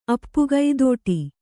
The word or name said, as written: ಅಪ್ಪುಗೈದೋಟಿ